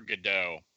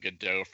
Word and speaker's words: Godot: The actors redid the pronunciation of Godot from the French Godot to Godot to make sure everybody got it.